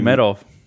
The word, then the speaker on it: Nurmagomedov: Nurmagomedov. – The UFC guy. – Nurmagomedov, there you go.